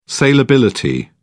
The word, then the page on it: saleability: British English